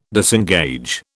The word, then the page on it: disengage: British English